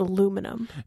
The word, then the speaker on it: aluminium: We've sourced all of the finest aluminium foil in the land to... – Oh, no, Brian, that's not how that word is pronounced. That word is pronounced aluminum.